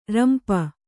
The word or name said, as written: ರಂಪ